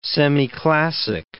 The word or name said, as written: semiclassic